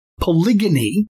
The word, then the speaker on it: polygyny: Last week, in episode number 270, 270, in one of the parts of that podcast, I shared why I pronounce the word polygyny, P O L Y G Y N Y, polygyny, with a hard G G sound and not the soft G J sound.